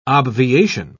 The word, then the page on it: obviation: British English